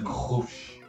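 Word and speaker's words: Grolsch: Ah, eu tô tomando uma Grosh, é assim que pronuncia mesmo? – Grosh. – Grosh. – É, né, na garganta. G na, na Holanda pronuncia na garganta.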